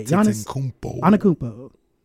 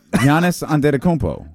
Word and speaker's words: Giannis Antetokounmpo: I just say Giannis Antetokounmpo. – Well, that's wrong. Nope. Okay. Well. – Giannis Antetokounmpo.